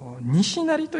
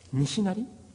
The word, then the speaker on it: 西成: 各地にあるそうですが、今までの中でおそらく日本で最大と言われる飲みの賭博場が大阪の西成と読むんでしょうか。西に成功の成と書いて西成、西成というところですね。